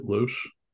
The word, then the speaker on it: Loach: You think that's how you say it, Loach? – Loach, yeah, that's how I've always pronounced it in my head.